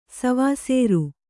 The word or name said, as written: ಸವಸರಿ